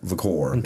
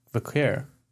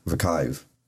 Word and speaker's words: Vaquer: Give me more Vaquer. That's not how you say it, though. – No, it's Vaquer. – Vakive. Stephanie Vakive in 2025.